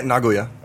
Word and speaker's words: Nagoya: Actually, is that how you pronounce it? How do you pronounce it? – Yeah, Nagoya.